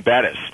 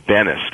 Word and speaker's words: Bettis: And then there was another player there, Jerome Bettis. And our cameraman gets Bettis' name wrong, and they call him Bennis.